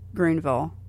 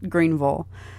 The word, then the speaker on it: Greenville: And if you've ever wondered how to pronounce Greenville, it's at least for the locals, it's Greenville. And because I know it looks like Greenville, and I know a lot of people say it that way, but just food for thought, right?